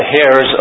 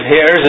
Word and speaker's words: heirs: If you don't know that it's a silent H, then you're going to think we're the heirs of God. Like God has hairs, and it kind of defines a few doctrines, doesn't it?